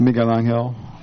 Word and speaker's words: Miguel Angel: Yes, it's Miguel Angel.